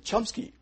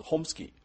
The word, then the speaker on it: Chomsky: 要介绍一个概念，就是说我们每一个人都有那个语言能力这个是现在那个语言学大师这个 Chomsky 翻成那个中文，有些人翻乔姆斯基如果是大陆的那个学者，但是呢最早的翻译是杭士基。那为什么翻成杭士基呢？其实这个 Chomsky 他可能那个早期是从那个俄罗斯那边移民到美国，所以这个名字英文是念成 Chomsky，可是呢，它本来的语言是 Homsky，所以为什么会这个把它念成那个宽成那个航式机？